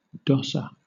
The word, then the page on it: dosser: British English